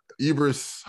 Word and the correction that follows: Idris: Idris Elba.